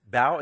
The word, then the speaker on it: bow: Oh, it's bow. It's not bow. Okay, why do you spell it B-O-W? Okay, bow.